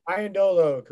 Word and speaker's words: Iandolo: Why don't you talk a little about your buddy, Mike Iandolo? I can't remember how to say it correctly. – Iandolo, correct.